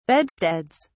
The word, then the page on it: bedsteads: British English